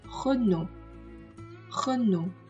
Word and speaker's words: Renault: 它的朗读有一些特殊性，在于它最后两个字母是不发音的，所以我们应该读成 Renault，Renault。